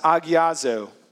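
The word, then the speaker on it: agiazo (ἁγιάζω): This word sanctify in the original language, which is Greek, is pronounced agiazo.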